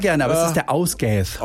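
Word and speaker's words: Ásgeir: Wäre der Ausgath, so spricht man ihn aus, ein isländischer Sänger.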